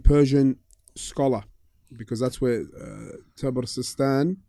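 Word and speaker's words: Tabaristan: With a ta, Tabristan.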